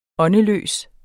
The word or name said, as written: åndeløs